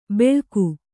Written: ಬೆಳಕು

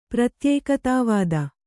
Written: ಪ್ರತ್ಯೇಕತಾವಾದಿ

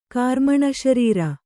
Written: ಕಾರ್ಮಣಶರೀರ

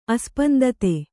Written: ಅಸ್ಪಂದತೆ

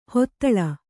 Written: ಹೂದಳ

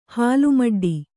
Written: ಹಾಲ್ಮಡ್ಡಿ

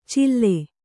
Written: ಚಿಲ್ಲೆ

